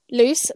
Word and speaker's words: Luce: My main channel is TikTok, which is @_ and then Footloose, spelled like a foot and then loose, like LUCE, which is my name.